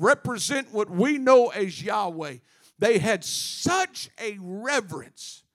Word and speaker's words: Yahweh: The closest we can come in pronouncing this name, y'all got to catch this, is Yahweh or Yah.